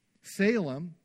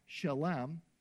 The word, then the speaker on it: Salem: Salem, which can also be pronounced Shalem, which is related to the Hebrew word shalom, means peace.